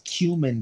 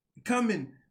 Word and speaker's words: cumin: It's cumin. – Cumin, yeah. Cumin. I just kept saying, coming, I'm coming.